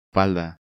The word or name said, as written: Falda